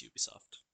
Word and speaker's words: Ubisoft: Ubisoft. – It's Ubisoft? I wasn't too far off. – Ubisoft. – All right, gentlemen, time for the biggest debate. Is it Ubisoft or Ubisoft? – It's Ubisoft.